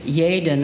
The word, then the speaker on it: Yaden: The book's author is David Yaden. It's spelled Y-A-D-E-N. I bet he has to spell it a lot. Not with a J, with a Y.